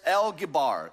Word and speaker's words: El Gibor: Well, that phrase mighty God actually in the Hebrew is the Hebrew words El Gibor, El Gibor.